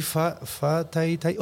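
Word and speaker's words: Whatatai: Y se escribe Huatatai. No se escribe Fatatai, se escribe Huatatai. – Es como los alemanes, ¿no? Que la W la dicen V y la V la dicen F.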